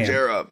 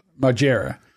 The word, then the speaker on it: Margera: Paramount's inhumane treatment of Marjana. Is that how you say his name? What was it? – Bam. Margera. – Margera.